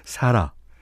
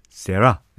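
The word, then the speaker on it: Sara: 하지만 발음은 사라 아니면 세라.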